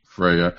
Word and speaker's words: Freya: Yeah, Odin showing up and they thought they were taking them back to Asgardia to be punished by Freya, or however you say her name. – Freya.